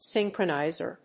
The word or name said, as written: synchroniser